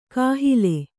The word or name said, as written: ಕಾಹಲೆ